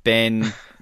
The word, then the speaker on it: Ben: My name is Ben, a.k.a. Man Sandwich at 12 o'clock, and Ben rhymes with hen and pen and ten and, I don't know, a lot of other words.